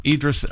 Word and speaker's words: Idris: We have an, is it pronounced Idris or Idris Elba? – Idris Elba. Idris. – Idris. I was way off.